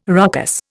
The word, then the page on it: ruckus: Indian